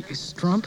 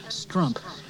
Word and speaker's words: Strump: Perry Strump. – Huh? – Perry Strump. – Perry John. – Strump. – Yeah, okay. – It rhymes with hump.